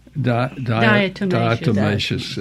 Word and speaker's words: diatomaceous: I wanted to ask about diamaceous earth. Am I saying that right? – Yeah. – Diatomaceous. It has a T.